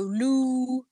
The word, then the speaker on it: Lughnasadh: according to what I've looked at, and I've got like a what do you call it when you have a pronunciation, and it does go Lughnasadh. – All right, then I said it right.